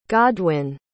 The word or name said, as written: godwin